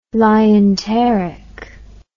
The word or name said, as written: lienteric